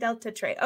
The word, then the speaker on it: Deltatre: It sounds more French than English. – Very close. Deltatre. It's actually Italian. – Ah, Delta. Okay, so I was pronouncing it more with a French name, but it's Italian, Deltatre.